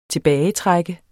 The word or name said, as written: TILBAGETRUKKEN